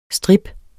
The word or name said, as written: strip